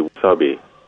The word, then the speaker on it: wasabi: Well, Japanese pronunciation would be wasabi.